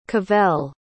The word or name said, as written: cavell